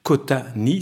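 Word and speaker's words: Qôta-Nîh: Alors j'épelle le titre Q O accent circonflexe T A trait d'union N I accent circonflexe H Cota Ni, c'est ainsi que je le prononcerai.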